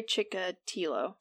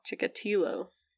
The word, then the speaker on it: Chikatilo: His name, I'm pretty sure, is pronounced Andrei. Is that correct? – I think it's Andrei Chikatilo. – Chikatilo.